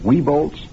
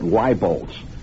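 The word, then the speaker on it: Wieboldt's: Well, have you heard the department store called Weebolts? More of these newscasters locally are mispronouncing it, calling it Weibolts.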